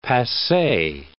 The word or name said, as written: passe